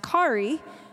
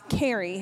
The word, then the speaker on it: Kauri: it's pronounced Kari and not Carrie. And so once again, they spelled my name K-A-U-R-I.